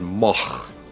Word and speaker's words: Moog: Well, there are three ways of pronouncing M-O-O-G. It's originally a Dutch name. This goes back, you know, 1,000 years or so. And in Holland, you would pronounce it Moch. Now, that's a little bit too much to expect people to do here. In Germany, it's a fairly common name, and there it's pronounced Moog.